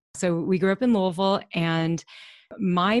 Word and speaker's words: Louisville: Did I say that correctly? Louisville, Kentucky? – Louisville, yeah.